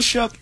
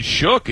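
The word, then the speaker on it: Shough: Like, they are a good, I don't know how he gets that pronunciation out of the spelling, but he is a very good quarterback right now. – So what's the correct pronunciation? – I mean, it shook. It looks like show to me, right? Where did you get the k sound? It's an O-U-G-H.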